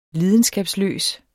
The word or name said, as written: lidenskabsløs